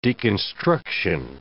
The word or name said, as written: deconstruction